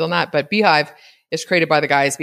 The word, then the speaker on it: Beehiiv: It's, I'm guessing it's Beehive, but it's spelled B-E-E-H-I-I-V. So it looks like Behiv.